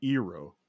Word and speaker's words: Iroh: The appropriate pronunciation would be Iroh for the uncle.